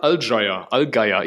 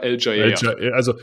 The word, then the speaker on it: Allgeier: Ist auf jeden Fall nicht Allgeier, aber das war mir natürlich klar. Also Allgeier, aber Allgeier geschrieben für uns deutschen Kartoffeln. Genau.